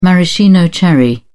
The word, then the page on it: maraschino cherry: British English